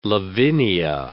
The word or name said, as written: Lavinia